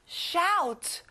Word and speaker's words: shout: 소리 지르다, shout. OU는 OW 소리 나요. Shout.